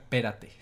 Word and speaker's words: espérate: Ah, bueno, solamente quiero agregar y explicar, espérate. Qué bueno, espérate... con acento en la primera E, espérate, es una forma de decir espérate o espera.